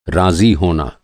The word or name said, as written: राज़ी